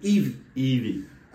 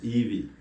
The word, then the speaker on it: Yvey: Yvey. – I saw the Y and the V. I used to be like Vess or yeah, I didn't get it. So Yvey. – Yvey. It's like Eve.